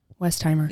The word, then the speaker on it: Westheimer: It's uh Westier, West Weistheimer, Weistenheimer, Westheimer, Westheimer, Westheimer. – Yeah, Dr. Ruth Westheimer.